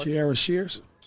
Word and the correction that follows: Sheard: Kiara Sheard.